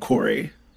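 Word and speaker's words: Krey: So it's K-R-E-Y. And we wanted like a double syllable kind of E sound. So we agreed on Corey.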